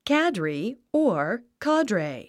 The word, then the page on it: cadre: kǽdri; キャァドレェイ